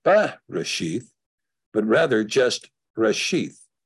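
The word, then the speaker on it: Reshith (ראשית): So our last letter being the Tav, pronounced in this case, some people say sheet, but in this case, TH, Reshith, Reshith.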